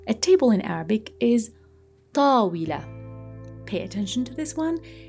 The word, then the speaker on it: طاولة: It's not طويلة, which means tall or long, not طويلة, طاولة. There is an emphasis on the first a sound in table. Table is طاولة.